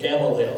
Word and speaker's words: Gamaliel: That's how you say it, Gamaliel.